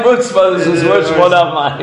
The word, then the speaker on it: Armani: Armani.